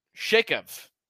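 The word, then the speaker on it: Xhekaj: Arbor Shakev, I believe is how you pronounce it.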